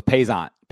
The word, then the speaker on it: Paisant: Marc Paisant, is that right? – Close. Paisant. Yeah. Yes.